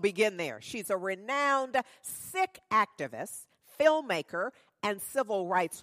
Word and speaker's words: Sikh: Now, I always thought the word was pronounced Sikh. She is Sikh and pronounces it Sikh.